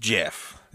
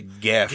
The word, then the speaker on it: Gef: Up next is Jeff, spelled G-E-F. – Nope. – In September... – G-E-F? – G-E-F. – Not even G-E-O-F-F? – Just Jeff. I guess you could say Geff. – Geff. – But on Wikipedia, it says it's Jeff.